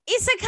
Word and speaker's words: Isekai: So our topic for today is the lovely, lovely world of Isekai.